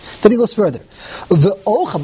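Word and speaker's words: ochel (אוכל): It's milara. You put the emphasis on the end, the chof, ochel.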